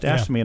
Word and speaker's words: AFSCME: How do you pronounce AFSCME. – That? – AFSCME. – AFSCME. – Yeah. – Just AFSCME, and I'll tell you how to pronounce it.